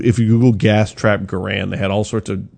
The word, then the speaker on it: Garand: And John Garand came up with Garand is the correct pronunciation of the man's name.